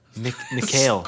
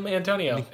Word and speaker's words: Mikhail: Mikhail. – Scoring goals. – Mikhail Antonio.